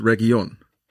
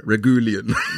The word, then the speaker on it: Reguilón: Nacho, did you see how he pronounced Reguilón? – No. – Regulian.